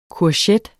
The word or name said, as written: courgette